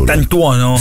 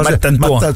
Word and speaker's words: Mastantuono: Lo chiamano Mastantuono? – No, lo chiamano, ma non c'hanno tutta una pronuncia tutta loro. – Mattantuono? – Sì, bravo, tipo una Mattantuono.